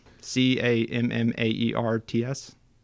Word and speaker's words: Cammaerts: And so Chesterton was the English yachtsman who discovered England, meaning um, he, as, as this Belgian writer, uh, how do you pronounce the name? Cammaerts? C A M M A E R T S? I'm going to go with yes. Yes, Cammaerts. Okay.